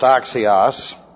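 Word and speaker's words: axios (ἀξίως): The adverb axios, which looks like this in the Greek, A-Z-I-O-S, pronounced axios, refers to the construction and function of the edification complex of the soul.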